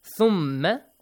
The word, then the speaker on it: ثُمَّ: If you have problem doing this, ثَ, the same as that, you got to bring your tongue out a little, okay? Bite it a little, not too much hard to hurt yourself, okay? ثَ. ثَ. ثَ. ثَ. ثَ. ثَ. ثُمَّ. It is the same as the pronunciation of TH in English that we have.